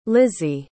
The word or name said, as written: lizzy